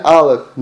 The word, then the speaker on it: Aleph: Now remember the letter Aleph. That's the first letter of Anochi. Is silent. Aleph is silent.